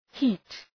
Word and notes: heat: hi:t

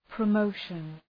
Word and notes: promotion: prə’məʋʃən

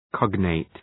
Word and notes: cognate: ‘kɒgneıt

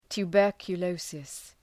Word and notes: tuberculosis: tu:,bɜ:rkjə’ləʋsıs

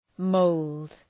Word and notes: mould: məʋld

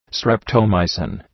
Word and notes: streptomycin: ,streptəʋ’maısın

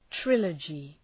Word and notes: trilogy: ‘trılədʒı

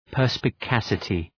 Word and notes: perspicacity: ,pɜ:rspı’kæsətı

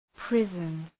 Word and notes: prison: ‘prızən

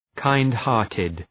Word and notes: kind-hearted: ,kaınd’hɑ:rtıd

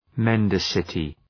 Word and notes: mendacity: men’dæsətı

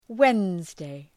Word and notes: Wednesday: ‘wenzdı, ‘wenzdeı

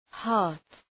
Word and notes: heart: hɑ:rt

